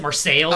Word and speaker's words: Marseilles: It's Marseille, right? And in Illinois, they pronounce it Marseilles. – I was just going to say that. I did some work down there, and if you mispronounce that and use the French pronunciation, they'll correct you. We do not say it that Marseilles.